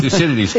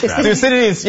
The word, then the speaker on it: Thucydides: I cannot pronounce the name of the Greek philosopher that Chinese president mentioned. Do you know what? Thucydides. – Thucydides, yes.